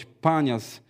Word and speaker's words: Banias: Cezarea Filipowa znana jest również z Banias, chociaż powinno się to wymawiać Panias, grupy źródeł oraz miejsca pogańskiego kultu bożka Pana.